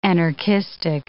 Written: anarchistic

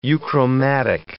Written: euchromatic

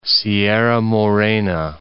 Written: Sierra Morena

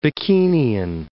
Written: Bikinian

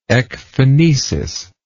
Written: ecphonesis

